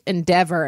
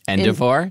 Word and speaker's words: endeavor: You know, I happen to know that as a teenager, I once mispronounced endeavor and pronounced it endivore? – Endeavor. Yeah, that's right.